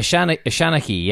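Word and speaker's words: seanchaí: I hope I'm saying this right. Shanaki, is that right? – Shanaki, yeah. It's a storyteller.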